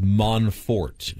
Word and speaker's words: Monfort: I think it's pronounced Monfort. – What'd I say? – Well, you're confusing the O's in his name with A's. Oh. I think you were replacing a little A with the O.